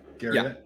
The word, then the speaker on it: Garriott: Garriott.